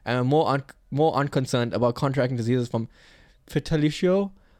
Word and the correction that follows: fellatio: I always say fellatio.